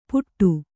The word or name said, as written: ಪುಟ್ಟು